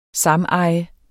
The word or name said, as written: sameje